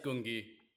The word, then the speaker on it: Gungi: And how they've been incorporated into the different battles, and then also the introduction of is it Goongi, is how it's pronounced? – Yeah, it's Gungi.